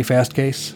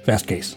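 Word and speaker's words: Fastcase: Did I say Fast Case? Did I say it correctly? I didn't. Fast Case.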